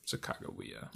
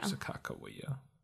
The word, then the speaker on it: Sacagawea: All of the pronunciations had that long E at the end, the E in fleece. – Sacagawea. – Yeah. – Sacagawea.